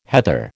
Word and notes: heather: American English

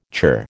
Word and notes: churr: American English

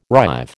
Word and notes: rive: American English

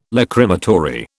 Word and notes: Lachrymatory: British English

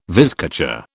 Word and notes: vizcacha: British English

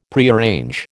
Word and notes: Prearrange: American English

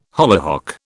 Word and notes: hollyhock: British English

